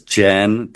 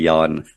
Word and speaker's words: Jan: There's so much to talk about, but for the other gentleman who didn't know how to pronounce my name, you can either pronounce it as Jan, that would be the way an American would normally pronounce it, or if you want to do it more closely to the Afrikaans, just change it to a Y and just say Jan. That's fine. either one is perfect.